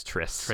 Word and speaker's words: Triss: Also, it was Triss. We were saying that wrong. – Oh, I was saying Trish. – Oh. Trish. Yeah, it's Triss.